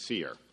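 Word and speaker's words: SERE: This was the military's Survival, Evasion, Resistance, and Escape Program, pronounced SEER, designed to teach pilots,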